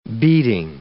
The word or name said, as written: beating